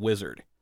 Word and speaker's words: wizard: And you can actually tell because Jack Haley is from Boston, so he doesn't pronounce the R in wizard. But if you listen in some of the group parts, Buddy Ebsen, he's got that hard R in wizard.